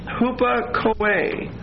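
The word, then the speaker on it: hupakoe (ὑπακοή): The word obedient is hupakoe. Hupakoe. H-U-P-A-K-O-E. And that's a long E. H-U-P-A-K-O-E.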